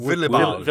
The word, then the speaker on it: Willibald: Willibald.